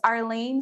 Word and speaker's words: Arleen: For the cannabis nursing herbalism piece, it is One Dope Nurse Arlene. So the number one dope nurse. And then my name is spelled A-R-L-E-E-N.